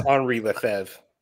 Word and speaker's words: Henri Lefebvre: I mean, if you want to anglicize it, it's Henri Lefebvre. – Henri Lefebvre. – Yep. – Okay. – Yeah, the B throws me off. – It's a silent B.